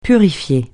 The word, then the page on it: purifier: francais